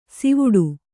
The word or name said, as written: ಸಿವುಡು